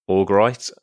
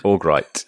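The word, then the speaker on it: Örgryte: Oh, well, how do you say it? – Augrite. – It's like troglodyte or something, isn't it? – Augrite.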